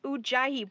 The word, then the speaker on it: Ujjayi: It's Ujjayi breath, or a deep diaphragmatic type of breath.